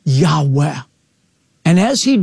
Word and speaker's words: Yahweh: It's known as the Tetragrammaton, and most Semitic scholars believe it's pronounced a breathy, Yahweh.